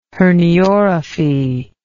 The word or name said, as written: herniorrhaphy